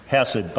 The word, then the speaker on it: hesed: Now that word we translated unfailing love is the word hesed. It's supposed to be pronounced with a hard H, hesed.